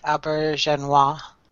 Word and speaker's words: Auberjonois: I always mispronounce that. – Yeah, he's one of the great character actors, and I always screw up the pronunciation of his name. – Yeah, but I think it's something like Abergenois. – Abergenois, thank you.